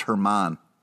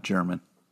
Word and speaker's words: Hermann: I also think it's pronounced Hermann, not German.